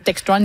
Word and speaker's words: Textron: Textron, il le prononce comme ça en anglais, évidemment.